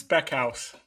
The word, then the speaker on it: Speckhals: Yeah, it's Speckhouse. It's uh, Speckhaus. – The Americanized way of saying a German name, at least.